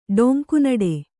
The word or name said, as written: ಡೊಂಕುನಡೆ